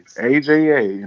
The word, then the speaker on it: Aja: The only reason why I'm saying Aja because it's spelled the same way as my friend who's a radio host on iHeartRadio spelled the same way AJA, and she pronounces it Aja, and crazy from the island, but.